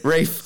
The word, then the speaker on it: Ralph: And then I have Ralph Fiennes from Rafe. – Rafe. See, you said it wrong so many times.